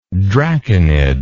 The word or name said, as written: draconid